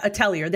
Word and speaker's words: Atelier: Is it Atelier Ryza Games? – I've heard of Atelier. Them, but I've never... Yeah, Atelier.